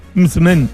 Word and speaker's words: msemen: Es una palabra complicada, decía, es M-S-E-M-E-N. Mzmen, mzmen, ¿vale? Y se pronuncia como lo ha hecho Hakima.